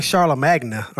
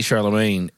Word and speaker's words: Charlemagne: What was so wrong with Charlemagne, though? Like, the silent G? – How to spell, yeah. – I guess. – But I would say like Charlamagna or something.